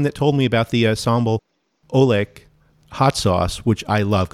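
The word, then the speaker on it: sambal oelek: And did you use the sambal, I don't know exactly how to say it, sambal olek from Haiphong? – Olek, potato, yes, that's what I used.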